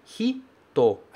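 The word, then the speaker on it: Hito (人): Hito. A gente não pronuncia esse I. A gente fala Hito. Hito. Direto. Do H para o T.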